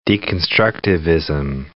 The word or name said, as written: deconstructivism